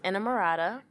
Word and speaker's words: Enamorada: It is Enamorada.